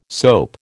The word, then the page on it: soap: American English